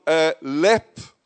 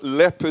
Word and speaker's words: Leap: Con is from Lepp, spelt Leap. But Lepp is how you pronounce it, in West Cork.